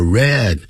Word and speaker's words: read: 同样是 read 要念成 are read， fun and exciting 就读起来有趣又刺激，好像这样子是比较对，你可能会这样想，认为比较可以。